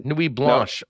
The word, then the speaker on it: Nuit Blanche: So Friday night Jets, then Saturday I went to Nuit Blanc. Am I pronouncing that right? – Nuit Blanche, no.